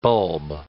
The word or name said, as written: bulb